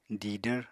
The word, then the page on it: didvr: apatani